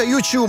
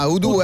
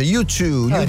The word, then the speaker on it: YouTube: Si pronuncia YouTube, ma noi diciamo l'italiana, U2, anche per la nostra amica professoressa d'inglese, YouTube.